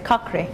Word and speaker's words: Cochrane: Ben de şu an üşendiğim için o yazı Kohran diye mi okunuş? Ben Cochrane. – Cochrane.